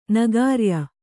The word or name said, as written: ನಗಾರ್ಯ